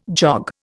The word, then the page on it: jog: Indian